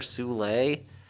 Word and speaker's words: Soule: Uh, my first choice is a book called Curse Words by Charles Sewell or Soule. It's spelled like soul with an E on the end.